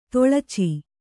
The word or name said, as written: ತೊಳಚಿ